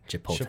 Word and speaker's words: Chipotle: Chipotle. The L comes after the T.